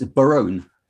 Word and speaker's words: Barone: It's Barone. Barone. See, I, I would have gone hard A, real American.